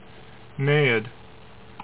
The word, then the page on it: Naiad: NAY ed